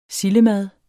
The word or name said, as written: sildemad